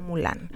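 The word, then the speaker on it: Mullane: hubo fue Patrick, se escribe Mulan, es uh-huh. como Mulan. Uh-huh.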